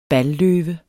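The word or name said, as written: BALLØVEN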